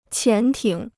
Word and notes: 潜艇: qián tǐng

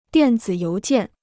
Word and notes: 电子邮件: diàn zǐ yóu jiàn

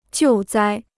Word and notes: 救灾: jiù zāi

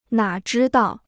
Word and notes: 哪知道: nǎ zhī dào

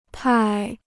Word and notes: 派: pài

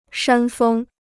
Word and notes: 山峰: shān fēng

